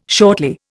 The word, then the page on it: shortly: Indian